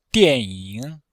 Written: dian4ying3

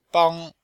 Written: bang1

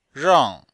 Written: rang4

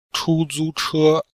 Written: chu1zu1che1

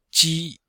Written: ji1

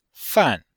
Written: fan4

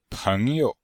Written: peng2you5

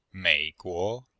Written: mei3guo2

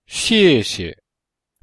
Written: xiexie

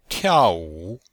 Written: tiao4wu3

